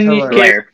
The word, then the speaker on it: Hilaire: Clyde Edwards, Hilaire, I don't know how to pronounce his second last name. Do you guys know how? – You said it right. – Hilaire? 10 Hilaire.